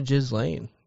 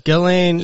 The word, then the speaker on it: Ghislaine: But I think it is Gizlaine. – Or Ghislaine? – It's Ghislaine. – Is it Jizlaine? A silent S? – We're just going to stick with Jizlaine.